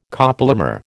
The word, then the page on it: copolymer: American English